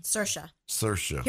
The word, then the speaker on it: Saoirse: Saoirse. Here's how you can remember. Saoirse. She always says it. Saoirse rhymes with inertia.